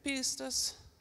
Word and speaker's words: pistis: Okay, so listen, that word "believe" right there in the original language, it's the word pistis. Everybody say pistis. No, come on, you can do it better than that. It's Greek, so you got to say it like that.